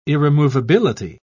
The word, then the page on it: irremovability: British English